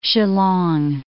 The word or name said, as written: Shillong